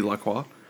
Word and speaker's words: LaCroix: If it's by a French pronunciation and, and not some sort of American butcherization of it, it would be LaCroix.